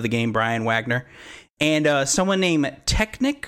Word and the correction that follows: Technique: Um, and Technic uh, might have messed is up a little bit with the Q at the end. Yes, it might be technique.